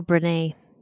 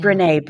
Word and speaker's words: Brené: Is it Brené Brown? – Oh, Brené. – Yeah. Brené Brown.